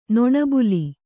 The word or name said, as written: ನೊಣಬುಲಿ